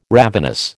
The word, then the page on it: ravenous: American English